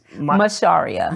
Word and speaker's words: Macharia: So it's Macharia.